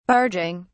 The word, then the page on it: barging: British English